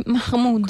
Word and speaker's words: Mahmood: Sì, perché lui preferisce questa pronuncia di Mahmood. – L'araba, sì, sì. – L'araba. Mahmood, certo. Onesta, a me piace più Mahmood.